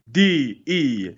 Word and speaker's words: De’Coldest: And for those that don't know, he is not lying. It sounds just like it's pronounced. D-E-C-O-L-D-E-S-T. Middle name, T-O-E-V-A-D-O-I-T. De’Coldest. – Don't sleep on the capital E, though. It's T-O, capital E-V-A.